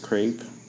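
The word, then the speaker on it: crêpe: My name is Crepe. – It rhymes with rape and grape.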